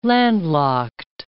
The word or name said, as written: landlocked